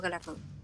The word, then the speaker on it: galardón: Parásito, la película surcoreana, se lleva el máximo galardón. O galardeón, ¿cómo se dice? – Galardón.